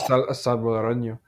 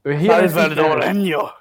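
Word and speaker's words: salvadoreño: In Spanish, it's Salvadoranio. Anyway. – Salvadoranio. – Salvadoranio. – That N, that's a Y.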